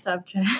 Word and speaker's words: Szewczuk: It's pronounced subject.